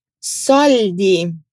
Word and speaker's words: soldi: Soldi. Carta de identidade, Patente. Soldi, o dinheiro. Você prestou atenção na pronúncia do L? A língua sobe no céu da boca e desce.